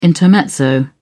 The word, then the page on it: intermezzo: British English